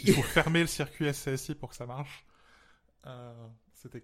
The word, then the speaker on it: SCSI: et c'est comme ça qu'il faudrait prononcer. – Ah ouais Ça va tellement plus vite en plus à dire, scusi.